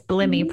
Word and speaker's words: Blimie: And it's Blimie for those of you who don't know, which I was pronouncing it wrong, but it's such a beautiful name. So yeah, most people pronounce it wrong because it's not a familiar name.